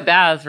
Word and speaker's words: Baz: Only if you can pronounce Baz, because I remember in undergrad, people were like, oh, yeah, we're going to Baz's party. I'm like, oh, we're going to Baz's party. And they wouldn't realize I was the same person. – What? – Which is why I'm like, no, you only can say Baz if you can pronounce Bathme. It's not hard. Three syllables.